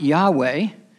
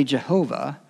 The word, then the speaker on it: Yahweh: We would pronounce it Yahweh, or alternately Jehovah, and it is the term that the Hebrews used for the eternal, self-existent God.